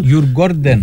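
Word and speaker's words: Djurgården: και για να κλείσει η μεταγραφή του στην Τζορ Γκάρντεν. Έτσι προφέρεται, με πήρε τηλέφωνο ο θείος. Σήμερα ο θείος, η σύζυγός του είναι από τη Σουηδία, μου το είπε, αλλά πάλι το ξέχασα. Τέλος πάντων. – Γιουρ Γκόρντεν. – Γιουρ Γκόρντεν, Τζορ Γκάρντεν.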